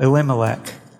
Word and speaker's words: Elimelech: Elimelech.